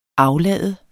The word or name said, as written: AFLADER